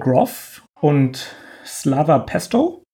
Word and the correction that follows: Pestov: ich weiß nicht, ob ich ihn richtig ausgesprochen habe, ähm, Pestov durch. – Pestov, ja.